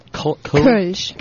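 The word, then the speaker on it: Kölsch: Kolsch. – Kolsch. – It's the Kolsch. That gets you. It's a Kolsch. – It's that stupid ooh.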